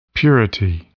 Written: purity

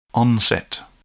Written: onset

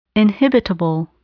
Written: inhibitable

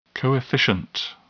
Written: coefficient

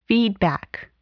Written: feedback

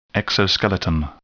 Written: exoskeleton

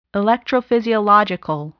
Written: electrophysiological

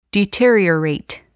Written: deteriorate

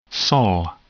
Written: sol

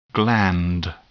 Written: gland

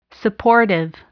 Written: supportive

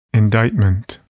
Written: indictment

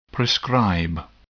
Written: prescribe

